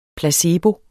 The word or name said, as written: placebo